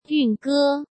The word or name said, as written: Yun Ge